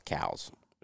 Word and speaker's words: Coues: Are you spelling it C-O-U-E-S? – Yes. Yeah. doesn't know what it is, man. – Yeah, it's pronounced cows, which is the only reason I remember that is because it's so weird.